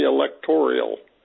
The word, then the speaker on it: electoral: That came from people who couldn't say electoral. It's election. It's elected. It's elect. The stress goes on the lec syllable, not the tor syllable.